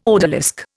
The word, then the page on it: odalisque: Indian